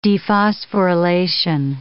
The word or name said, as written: dephosphorylation